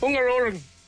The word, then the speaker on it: Hungaroring: Hungaroring.